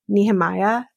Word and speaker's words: Nehemiah: It's like Jeremiah with an N. Nehemiah. There we go.